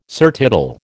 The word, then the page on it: surtitle: American English